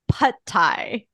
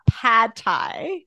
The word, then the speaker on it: pad thai: She was like, "It's pad thai. It's not pad thai.